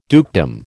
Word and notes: dukedom: American English

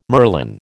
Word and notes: merlin: American English

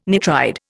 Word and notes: nitride: Indian

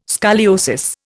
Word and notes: scoliosis: Indian

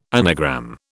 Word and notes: anagram: British English

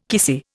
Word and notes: kissy: Indian

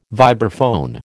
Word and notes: vibraphone: American English

